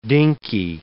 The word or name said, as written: dinkey